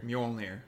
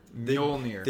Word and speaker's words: Mjolnir: You know how to say it, right? – Mjolnir. – Mjolnir. – Mjolnir. – Mjolnir. – Mjolnir. – Mjolnir. – The J makes a U. – Mjolnir. – Mjolnir. – There you go.